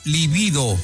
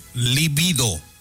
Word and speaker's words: libido: La palabra es la libido. – ¿Cómo? – Libido. – No es libido. – No, y no se dice libido, sino libido se dice.